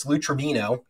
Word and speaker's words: Lou Trevino: It's Lou Trevino.